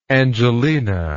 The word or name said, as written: Angelina